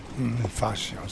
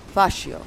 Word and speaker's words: fascio: The Italians decided that, and they named fascism, you might know this word better than me, how to pronounce it, that is. Fascismo is derived from fascio, meaning bundle of sticks. – Fascio, si. – Fascio.